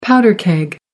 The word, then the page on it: powder keg: American English